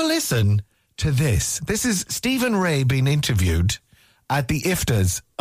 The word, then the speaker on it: Stephen Rea: How do you pronounce it? Stephen Ray, I think, isn't it? – Stephen Ray. – Yeah, the Northern Irish actor.